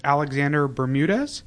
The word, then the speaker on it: Alexander Bermudez: Today we are joined in studio with Alexander Bermudez. Did I pronounce that correctly? – That is correct.